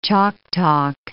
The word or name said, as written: chalk talk